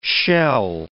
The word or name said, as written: shell